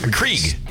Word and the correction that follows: Keurig: Keurig. – Keurig. Keurig. – Yeah. – You didn't even know how to say it.